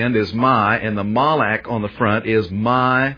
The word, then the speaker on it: malak: First of all, under the word angel, the Hebrew word in the Old Testament is the word malak. And I expect the best way to spell it in English would be M-A-L-A-K, malak.